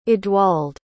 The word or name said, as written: edwald